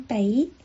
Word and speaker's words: 给: 送给，送 cup，送 cup，这是书面语的发音，口语是送北，送北。普通话的给予说明了给在普通话里面是一个多音字，但是粤语只有一个发音，书面语是 cup，口头语是北，是不是很有意思？